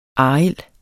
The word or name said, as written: arild